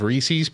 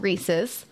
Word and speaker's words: Reese's: It's not spelled Reese's. It's spelled Reese's, just like Reese Witherspoon.